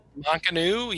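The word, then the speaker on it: Mac Anu: But we have our final song for the selection of music from this game, and this is the theme of, is it Mak'anu? Is that how you pronounce it? – Mak'anu, yeah.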